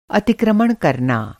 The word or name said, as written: अतिक्रमण